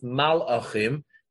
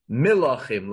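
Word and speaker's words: milachim (מלכים): When the kings, and here even though there's an aleph there, but it's not pronounced malachim, it's pronounced milachim, like kings. And the aleph is essentially just extra there.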